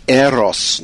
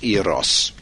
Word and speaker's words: Eros: Now, I've just had an SMS here saying it's pronounced Eros, not Eros, Italian. Well, I'm British and I say Eros.